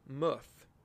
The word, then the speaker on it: Muth: His name is Harold Muth, or you could say Muth, but the way it's pronounced is M U T H.